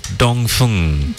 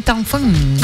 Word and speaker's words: Dongfeng: If you haven't heard about the Dongfeng series that's available at Euro Motors, it's actually pronounced Dongfeng. – Dongfeng. – Correct. – Yep. – But unfortunately, everyone's got used to saying Dongfeng.